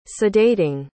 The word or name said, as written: sedating